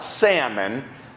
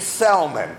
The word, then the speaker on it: Salmon: Not Salmon. A man named Salmon. Spelt the same, but pronounced different.